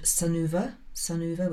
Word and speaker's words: Sunuva: I really like the funky children's sun clothing brand called Sunuva, which is spelt S-U-N-U-V-A, Sun U-V-A.